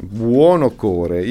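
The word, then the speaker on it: Buonocore: Abbiamo cominciato con un pezzo di Nino Buono Cuore, cioè Buono Core. Io da ragazzo non riuscivo mai a pronunciare bene il suo cognome, dicevo sempre infatti, Buono Core.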